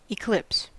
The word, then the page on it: eclipse: American English